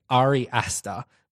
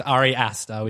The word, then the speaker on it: Ari Aster: And it's not Ari, it's Ari Aster. That's because you guys were trying to pronounce it on the podcast. It's Ari Aster, which is a bit weird.